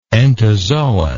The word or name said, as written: entozoan